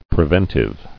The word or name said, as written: Preventively